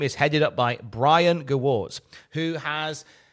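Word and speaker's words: Gewirtz: Gewurz. Yeah, I got his book, and one of the things it starts up was how to pronounce his name. – Ah, Gewurz. – Yeah. As in, he's the worst.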